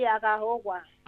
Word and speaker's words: Aghehowa: ¿Lo he dicho bien el apellido? – Sí, agaogua.